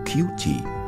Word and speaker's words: QT1: Die Maschine heißt QT1. Englisch ausgesprochen klingt das wie Cutie, also Süße, Süßer oder Schätzchen.